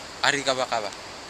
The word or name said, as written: arigawagawa